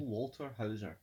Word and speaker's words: Paul Walter Hauser: It is from Paul Walter Hauser, I believe is how you pronounce it. Paul Walter Hauser.